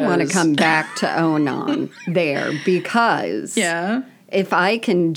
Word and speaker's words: Onan: And then second, Onan, am I saying his name correctly? Like Conan? Onan? – Well, from what I remember of New Testament. – Onan.